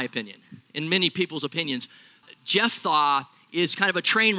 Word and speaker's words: Jephthah: Now, say Jeph. It's the PH kind of F sound. Jephthah, like Jeph's thawing out. That's how we're going to pronounce it. I'm going to try to pronounce it that way.